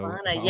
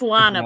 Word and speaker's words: Lana: Lana, yes. – Hope, I pronounced it right. – It's Lana, but you were close. Lana. – Most people say it the way you did.